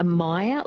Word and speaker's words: Meyer: Elle has texted through and she's asked a question, about myoria. She says, does the iron issue apply to myoria as well? – So maybe a Meyer lemon, so M-E-Y-E-R. – That's it, yes. Excuse my bad pronunciation of that.